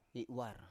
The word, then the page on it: i7war: Biak